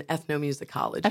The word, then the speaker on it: ethnomusicology: I have a degree in ethnomusicology. – Okay, I was going to have it right. But tell us what that is. – Ethnomusicology is the study of music across different cultures.